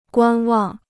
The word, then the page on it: 观望: guān wàng